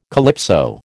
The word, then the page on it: calypso: American English